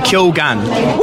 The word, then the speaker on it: Keoghan: And I asked today, his agent today, it's officially Keoghan. – When did that happen? – Is that not a posh way of saying it? – I didn't know that was a pronunciation for Keoghan. – Always said Keoghan.